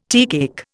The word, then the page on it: teacake: Indian